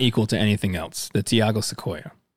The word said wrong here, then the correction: Tioga: I might have said this wrong. So this is spelt Tiago. This is Tioga.